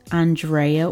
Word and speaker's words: Andrea: I am your snowed in host, Andrea, or Drea if you butcher the A-N of my name.